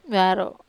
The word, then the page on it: varo: Wamesa